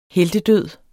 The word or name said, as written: heltedød